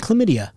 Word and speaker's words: chlamydia: He did not know that there was an H in chlamydia. Who knew? – Chlamydia. – Yeah, it's a silent H.